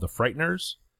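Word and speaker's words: FRIGHTNRS: My number three this week is an album by a group called The Frighteners, but spelled like Flickr. There's no E at the end of it. It's F R I G H T N R S.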